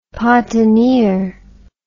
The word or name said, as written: Patinir